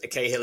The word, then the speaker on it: Cahill: My name is Adam William Cahill in the Irish tongue, but I say Cahill the same because Cahill